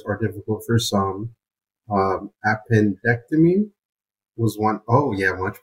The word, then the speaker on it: appendectomy: Appendectomy. Did I say that right? Yes. – Good. He's going to have his appendix removed. You missed an N the first time, but appendectomy.